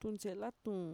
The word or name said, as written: tuntxe'elatuun